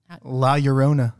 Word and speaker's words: La Llorona: La Llorona. – La Llorona. – La Llorona. You ever heard of this tale? Daisy? – This is my first time hearing about this. – The La Llorona or the crying woman of Gilead? – Both.